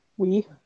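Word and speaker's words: Ng: Kim, and I don't know how to pronounce her last name, N-G. – Wee, I think.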